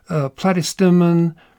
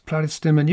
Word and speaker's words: Platystemon: Oh, a few years ago, during an amazing poppy spring, there was this beautiful display of a wildflower called cream cups, Platystemon californicus, Platystemon.